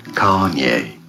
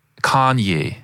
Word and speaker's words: Kanye: Kanye. – Kanye, finde ich, sagst du super. – Ich glaube, die Betonung ist auf der ersten Silbe.